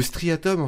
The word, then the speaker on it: striatum: Le striatum.